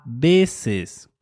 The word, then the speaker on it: veces: Next up is sometimes, which in Spanish is a veces, composed of two words. A, preposition a, plus veces, which is spelled V-E-C-E-S.